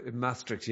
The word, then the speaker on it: Maastricht: They're connected with researchers at Maastricht. Is that how you say it, university? – Maastricht, yeah. That's Maastricht.